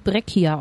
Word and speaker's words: Breccia: Ich muss dich kurz korrigieren, der wird Breccia ausgesprochen.